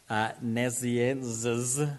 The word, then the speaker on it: Nazianzus: Gregory of Nazianzus, which is exactly how you pronounce it, church father, lived a long time ago, he was talking about the incarnation, and he said this.